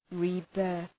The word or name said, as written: rebirth